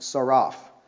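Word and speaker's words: tsaraph: The word try here is the word saraph, and it's T-S-A-R-A-P-H. It's pronounced saraph.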